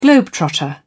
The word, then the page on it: globetrotter: British English